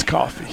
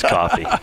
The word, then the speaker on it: Coffey: Right-hander number 8, Gavin Coffey, and it's spelled C-O-F-F-E-Y. – Coffey. – Gavin Coffey. – Coffey? Coffey-fey. – The boy's from Texas. It's coffee. – Yeah, I mean, I guarantee it's coffee.